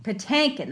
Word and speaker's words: Patinkin: Patinkin.